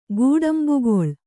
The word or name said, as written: ಕಟ್ಟಂಬಕಳ